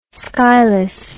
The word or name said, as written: skyless